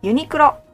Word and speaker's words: Uniqlo: I mean, I can still understand, recognize it when you say Uniqlo, but it still sounds a little bit odd to me. Uniqlo, that's our pronunciation.